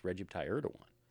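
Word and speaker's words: Recep Tayyip Erdoğan: Recep Tayyip Erdogan.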